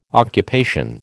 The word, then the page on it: occupation: American English